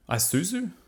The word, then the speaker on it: Isuzu: Isuzu, Isuzu, yes, that's it.